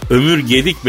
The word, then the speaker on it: Ömür Gedik: Buradan da Tunceli hemşerilerime, severlere ve Ömür Gudik ablamıza sonsuz saygılarımı iletiyorum. Ömür Gudik kim ayol? Ha, yanlış telaffuz etti çekici. Alo abi Ömür Gudik değil hanımefendi ismi. Ömür Gedik benim de e, arkadaşımdır.